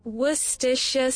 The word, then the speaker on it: Worcestershire: Worcestershire sauce. – Just by the way, that's apparently the proper way of saying it. Now, if we get her to say it slower. – Worcestershire sauce.